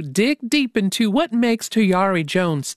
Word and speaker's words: Tayari: Let's get that name right. Tayari. – Mm-hmm. Rhymes with Ferrari and Oops, Atari.